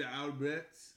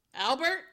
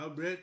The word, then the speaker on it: Albret: Called Les Grandes Caves de Albret. – Albert? – Albret. – Albert. – Albret. – Oh, it is Albret.